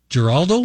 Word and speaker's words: Giraldo: Giraldo? Is that right? Did I say that right? Okay. Thank you.